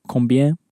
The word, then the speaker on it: combien: The word for how much sounds a little bit like the combine in combination, but it's instead it's combien.